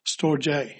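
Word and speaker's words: storge (στοργή): It's spelt storage, but it's pronounced storge.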